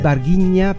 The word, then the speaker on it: Varginha: Claro, es Varginha, pero se escribe Varginha con H intercalada. – Como vagina.